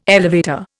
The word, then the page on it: elevator: Indian